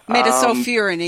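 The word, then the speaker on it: metsulfuron: The active constituent was melsulfuran, I think that's it. – Metasulfurin is how you say it.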